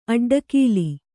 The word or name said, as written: ಅಡ್ಡಗೆಲ್ಲು